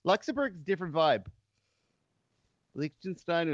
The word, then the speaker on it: Liechtenstein: We went to Liechtenstein? – Yeah. – Liechtenstein, if you want to say it properly, I think.